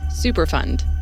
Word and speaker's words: Superfund: Superfund, emphasis on fund, not fun, was a program created by the federal government in 1980 to help pay for the cleanup of hazardous waste sites.